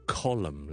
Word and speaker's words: column: 有 autumn 啦，column 啦，condemn 啦，都系 silent n sound。